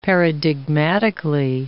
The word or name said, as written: paradigmatically